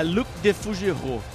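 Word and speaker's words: Luc de Fougerolles: Mas como é a pronúncia aí? – É Luc Defougerou.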